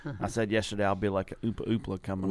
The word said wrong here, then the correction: Oompa Loompa: Oompa Loompa.